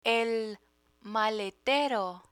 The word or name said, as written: maletero